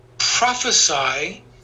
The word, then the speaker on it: prophesy: Prophecy is a noun. Prophesy is a verb. Do you see the difference in the pronunciation? Prophecy, prophesy.